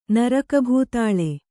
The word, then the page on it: ನರಕಭೂತಾಳೆ: Kannada